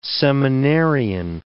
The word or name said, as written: seminarian